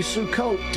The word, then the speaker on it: Sukkot: Abe, happy Sukkot to you. Did I pronounce it correctly? – Thank you. You nailed it.